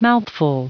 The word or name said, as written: mouthful